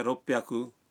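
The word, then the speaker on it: روپ پیاکو (六百): لیکن بعض اعداد کا تلفظ کچھ مختلف ہے۔ مثلاً 300 ہے سام بیاکو، 600 ہے روپ پیاکو، اور 800 کو کہتے ہیں ہاپ پیاکو۔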